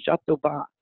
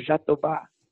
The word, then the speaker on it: jatobá: And the main ingredient in that is not graviola, but it's a rainforest tree called jatoba, J-A-T-O-B-A. – J-A-T-O, jatoba, interesting. – Uh-huh. Well, the English pronunciation phonetically is Jatoba, but it's pronounced in Brazil as Jatobá.